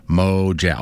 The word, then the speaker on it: Mojab: Also, Mojab should have a long A sound Mojack.